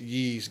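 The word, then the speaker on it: Ys: so the newest Yi's game, and Yi's is Y-S, it's hard to pronounce,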